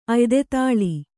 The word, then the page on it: ಅಯ್ದೆತಾಳಿ: kannada